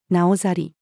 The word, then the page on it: 等閑: female